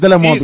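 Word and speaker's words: Delémont: Yeah, it's Delemont because silent. The T is silent. It's Delemont.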